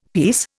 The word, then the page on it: pease: Indian